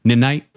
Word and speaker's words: Ninite: Yeah, but they pronounced it Ninite.